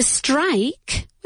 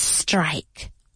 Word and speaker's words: strike: The strike. What is strike? Huelga. Cuidado con la pronunciación. Strike? No. Strike.